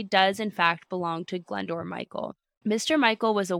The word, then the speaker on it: Glyndwr: Glendore, I listened to the pronunciation of this, guess how this is spelled? G-L-Y-N-D-W-R. Now we have no vowels.